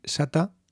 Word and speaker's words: sata: Cuidado con la pronunciación. No hay que confundir sata, estar satisfecho o satisfecha, con shata.